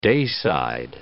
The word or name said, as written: dayside